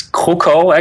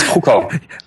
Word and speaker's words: Krukow: Yeah, it's Krukow, actually. Krukow. At least that's the Danish pronunciation of Polish name. So maybe you should ask someone Polish. It's Krukow.